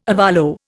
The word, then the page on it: ovolo: Indian